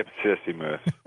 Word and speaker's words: Ipsissimus: Ipsissimus.